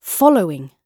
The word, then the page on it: following: British English